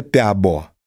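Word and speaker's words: Pääbo: И долгое время на этот вопрос нельзя было ответить, пока не появился такой человек, фамилию которого все, значит, произносят неправильно. Я могу ее первой произнести правильно. Это Сванте Пиабо. Пиабо.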